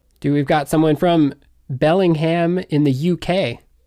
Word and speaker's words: Bellingham: And if I understand correctly, I've been taught this by some other fellow UK people there, that Bellingham over there is pronounced Bellingham. Bellingham. He kept saying, you guys aren't from Bellingham, you're from Bellingham. It's Bellingham.